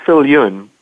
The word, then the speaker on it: Viljoen: It's pronounced Viljoen.